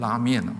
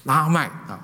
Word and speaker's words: 拉麦: 所以这个拉麦呢，就给他，注意不要读成拉面了，中国人很喜欢吃拉面是吧？拉麦，麦跟面其实有关的，拉麦希望在被上帝咒诅的这个劳苦的这个工作当中得着什么安慰与安息。